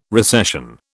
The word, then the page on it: recession: British English